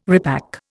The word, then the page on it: repack: Indian